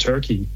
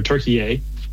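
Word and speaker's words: Türkiye: But episode 1 is up there, and people are just loving it, and just learn a ton about Turkey or Turkey-A. Mm-hmm. As the proper pronunciation, and Dave's just a great guy, great guide, and it's a lot of fun.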